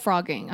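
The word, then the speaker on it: phrogging: Oh, it is called frogging. Okay. – Is a person secretly living in another person's home. The word is pronounced frogging, but it's not spelled like that.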